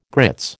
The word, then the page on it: grits: American English